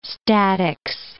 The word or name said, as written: statics